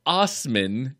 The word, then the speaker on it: Assman: this man's name, just for everyone listening, is spelled Assman. I'm gonna give you just a minute to think that out and spell it because I know you're slower than all of us. So Osman is how he pronounced his last name.